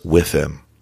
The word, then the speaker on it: WIIFM: is the tool or phrase WIFM. You may have heard this before. It's spelled W-I-I-F-M. And it stands for what's in it for me. The thing about the phrase WIFM, though, it's not about you and I, the person creating the resume, the job candidate.